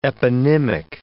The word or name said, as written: eponymic